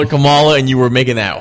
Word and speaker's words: Kamala: You were, it's pronounced Kamala.